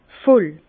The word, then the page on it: voll: Bas-Rhin; Herrlisheim